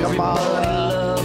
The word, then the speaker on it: Kamala: No, they're not. It's Kamala. – There's two ways to pronounce the name. There's one correct way for Kamala Harris.